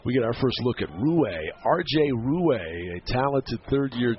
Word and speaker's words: Ruais: He spells it R-U-A-I-S, but pronounces it Rue.